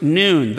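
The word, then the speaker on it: noon (ن): Red paint, pronounced noon, the 14th letter of the Arabic alphabet and the equivalent to the Roman letter N.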